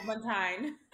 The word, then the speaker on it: Agbontaen: I want to say Ain, but it's not. Say it again. – Agbontaen.